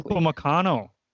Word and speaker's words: Upamecano: Dayot Upamecano. I hope I said that correctly. – Upamecano.